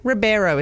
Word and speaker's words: Ribeiro: Ribeiro is how you pronounce his name, a.k.a.